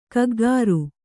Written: ಕಿಕ್ಕಿರಿ

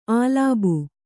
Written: ಅಲಬು

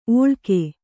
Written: ಒಳ್ಕೆ

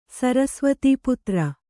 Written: ಸರಸ್ವತೀಪುತ್ರ

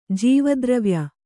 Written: ಜೀವದ್ರವ್ಯ

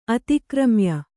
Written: ಅತಿಕ್ರಮ್ಯ

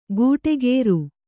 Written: ಗೂಟೆಗೇರು

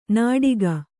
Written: ನಾಡಿಗ

